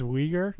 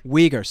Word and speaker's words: Uyghur: the Uyghur. Uyghur, right? – I think it's Uyghur. – Uyghur, sorry. I literally asked Taylor before.